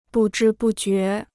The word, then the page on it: 不知不觉: bù zhī bù jué